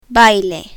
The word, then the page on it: baile: spanish